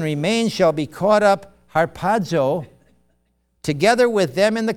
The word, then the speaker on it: harpazo: But anyway, the Greek word is harpazo. Did I pronounce that right? Thank you.